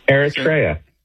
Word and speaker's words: Eritrea: Well, there's an African country just north of Ethiopia, and it's spelled E-R-I-T-R-E-A. – Eritrea. Yeah. – How would you say that? – Eritrea. – Eritrea. Thank you.